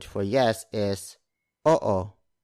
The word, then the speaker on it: oo: Oo. Oo. It's spelled as o-o. Notice that in Tagalog words with double vowels like oo, we pronounce the vowel separately. So it's not oo. It's oo. Again, oo. Oo.